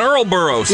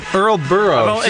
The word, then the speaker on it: Ouroboros: Ouroboros? – Yeah, an Ouroboros. – Yeah, Ouroboros.